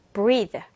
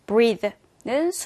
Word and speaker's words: breathe: Breathe. Breathe라는 단어가 있고요. 자, 여기에서 TH 발음에서 약간 the와 같은 유성음 소리가 나기 때문에 우리 주의 깊게 조금 이따 스피킹 클리어에서도 집중적으로 발음 연습해 볼게요.